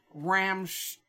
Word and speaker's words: Rammstein: Rammstein.